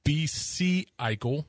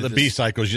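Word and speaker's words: B-cycle: then traverse downtown to B.C. Eichel. How do you pronounce that? On a bicycle. – B-cycles.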